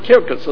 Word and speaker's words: circus: it's really the word kirkas in Latin, right? Of course in English we say circus because, you know, by us, a C before an I has to be pronounced like a S, right? Whereas in Latin, to begin with, it was pronounced like a K.